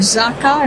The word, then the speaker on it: Zaka: We have with us today Simcha Grindman, who is the Deputy Commander of Zaka.